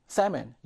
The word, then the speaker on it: salmon: 이거 L이 발음이 되지 않죠, 여러분들. salmon, 연어인데요.